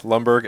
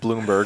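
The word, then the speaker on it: Lumbergh: X, Lumberg X, which you would think is Bloomberg for some reason. It's obviously why? Lumberg.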